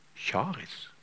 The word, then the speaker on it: Charis: Det ligner på, faktisk på gresk, så det gresk ordet for nåde. Charis, uttales det med.